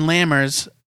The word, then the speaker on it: Lammers: And then the A in Lammers like you would in far. Ah.